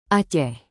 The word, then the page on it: aceh: indonesian